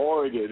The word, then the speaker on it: Oregon: From Oregon.